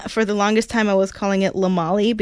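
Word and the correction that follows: Lamoille: because it's spelled L-A, capital L-A, and then capital Molly. So then I was like, okay, cool, we're going to Lamolly. And in fact, no, it's Lamoy, which is a very nice hiking trail, I will say.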